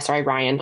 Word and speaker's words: Rian: Glass Onion, A Knives Out Mystery, written by Rian Johnson. Ryan. Ryan Johnson.